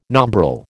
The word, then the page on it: nombril: American English